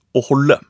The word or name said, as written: aa holde